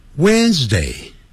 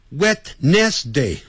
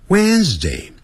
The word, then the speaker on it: Wednesday: Wednesday. 那个 WD 那个 D 不念出来的 When 更不要念成 Wednesday、啊、不是按照那个字母这样来念啊不要自己发明叫 Wednesday 不是这样子 Wednesday Wednesday 有声的 z、啊、 Wednesday today 啊，礼拜四呢？Thursday，那个舌头先吐出来，然后很快往内缩。